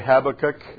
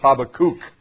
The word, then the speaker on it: Habakkuk: Some people say Habakkuk, some people say Habakkuk, and according to Hebrew pronunciation, it's Habakkuk.